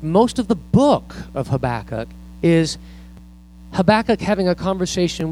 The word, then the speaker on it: Habakkuk: But there's this one guy named Habakkuk, and it's not pronounced tobacco. It's Habakkuk. And I can't imagine, though, having a little baby say, oh, let's name him Habakkuk.